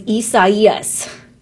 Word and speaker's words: Isaias: That would be a lot easier to pronounce, but it's Isaias. It looks kind of like Isaias, but it's not Isaias, it's Isaias.